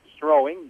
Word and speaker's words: Strowing: We got the correct pronunciation on Derek Strowing. Strowing, just like you're rowing a boat and strowing.